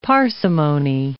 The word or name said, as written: parsimony